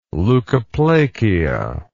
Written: leucoplakia

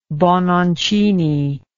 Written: bononcini

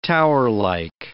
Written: towerlike

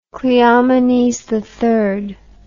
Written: Cleomenes III

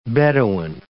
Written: beduin

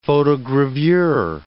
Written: photogravure

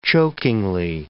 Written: chokingly

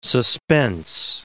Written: suspense

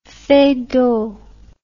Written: Feydeau

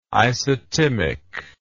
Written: isotimic

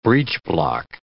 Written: breechblock

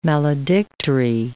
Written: maledictory

